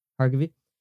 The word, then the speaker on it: Harkavy: So, the lead actress, Juliana, how do you pronounce this last name? – Harkavy.